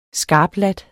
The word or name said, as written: skarpladt